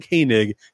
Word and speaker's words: Koenig: Kristen is one of the best follows on LinkedIn, but her name is not spelled the way it sounds. Kristen Koenig is spelled K-O-E-N-I-G. Kristen Koenig, K-O-E-N-I-G.